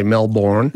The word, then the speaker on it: Melbourne: Although whether or not he pronounced the R is a bit doubtful. The pronunciation of the R in Melbourne. It would have been there originally, but it was on the way out in London English.